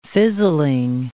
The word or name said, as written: fizzling